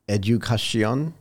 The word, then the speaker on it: Edukasyon: I'm going to try to pronounce this properly. Edukasyon? Did I get it? Was I even close? No. – You were you got it very well.